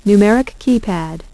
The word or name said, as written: numeric keypad